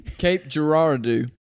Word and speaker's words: Cape Girardeau: Cape Girardeau.